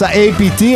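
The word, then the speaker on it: APT.: Rosé con Bruno Mars, APT o APTO, a seconda della pronuncia, sì.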